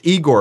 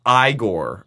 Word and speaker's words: Igor: It's not pronounced Igor, apparently. It's pronounced Igor.